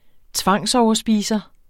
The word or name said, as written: tvangsoverspiser